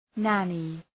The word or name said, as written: nanny